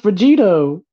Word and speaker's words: Vegito: Nope, Vegirot. And I'm like, come on. Anything. – It's pronounced Vegito. – They call it Vegirot. – Oh my God. I'm going to cry now. It's Vegito.